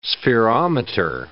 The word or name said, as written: spherometer